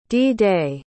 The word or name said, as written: d-day